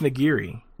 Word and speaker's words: Nigiri: It's a Japanese word. It's pronounced Nigiri.